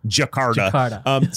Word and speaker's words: Jakarta: Jakarta. – Is that right? Yep. Yeah, Jakarta.